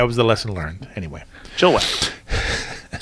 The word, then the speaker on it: Chilliwack: Like, is it is it Chilliwack? Is it Chilliwack? Is it Chilliwack? And Chillack. she's like, it's Chilliwack. Like, don't like, overthink okay.